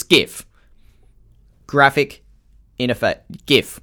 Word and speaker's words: GIF: I'm going to discuss the correct pronunciation of GIF. It's GIF. That's it. That's all there is to it. It's GIF. It's not GIF. If you say GIF, you're wrong.